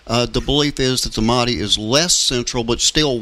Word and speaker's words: Mahdi: I say Islamic Mahdi, and he's got the correct pronunciation. But anyway, this Mahdi.